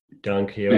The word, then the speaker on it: Don Quixote: And I chanced Don Quixote. Is that how you pronounce that name? – Don Quixote.